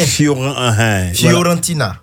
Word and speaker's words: Fiorentina: Inao là, il est à la Fiorentina. C'est en, en Italie. – En Italie, voilà. – Ouais. – J'espère que je prononce bien. – Tu prononces bien. – Fiorentina.